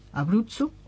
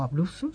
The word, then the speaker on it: Abruzzo: Abruzzo, o Abruzzo, si lo pronunciamos a la española, y Calabria.